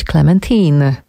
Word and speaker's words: Clementine: bo sam śpiewa Clementine, a w rozmowie z Maciejem Jankowskim, którą Państwo mogli usłyszeć na antenie Trójki, mówił, że ten tytuł należy wymawiać Clementine.